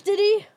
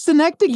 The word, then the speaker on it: synecdoche: Isn't it Schenectady? – No, Schenectady is a city. – No. – I believe that word is pronounced... It is Schenectady? I've been saying synecdoche. Oh, I just outed myself as a dummy. It is synecdoche.